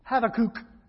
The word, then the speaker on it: Habakkuk: I looked up how you pronounce that in Hebrew. And it's Habakkuk. Literally, it's Habakkuk.